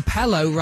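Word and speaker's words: Pellow: Wet Wet Wet are fronted by Marty Pello, and Pello rhymes with Yellow, who had a top 7 hit in 1988 with The Race, which is what we've got to do on Sunday in Newcastle.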